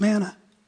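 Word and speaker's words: Man (מן): But you know how you pronounce manna in Hebrew? Man. Man.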